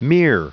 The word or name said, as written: mere